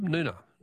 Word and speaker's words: Noonga: Noonga is pronounced with a silent G. Is it? Oh, thanks, Barb. Noonga.